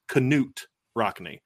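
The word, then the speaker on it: Knute Rockne: But it was just really cool to kind of see the Rockne family still has a passion for Notre Dame and what Newt Rockne. And they made the point too, like, it's actually they said this at the thing, the correct pronunciation is Canute Rockne.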